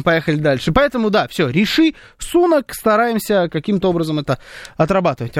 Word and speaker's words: Риши Сунак: Кстати, я вчера обещал вам узнать, как Риши Сунака правильно произносит BBC. Риши на вторую «и» ударение. Сунак. Вот так правильно звучит имя этого человека.